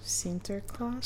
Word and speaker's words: Sinterklaas: Sinterklaas.